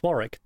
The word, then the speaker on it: Warwick: The fantasy film starred Warwick Davis. There's a W there. I think I'm saying it right. – Yeah, it's a place. It's Warwick.